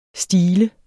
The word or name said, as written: stile